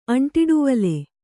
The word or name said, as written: ಅಂಟಿಡುವಲೆ